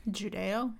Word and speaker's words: Judeo: Christmas is a religious holiday at its core, but outside of that, not just like Judo. Oh shit. – Judeo? – Yeah, Judeo-Christian. That's how you say it, right?